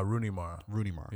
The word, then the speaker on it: Rooney Mara: that starred Casey Affleck and Runa Mara. Am I getting her name right? – Rooney Mara. Yeah.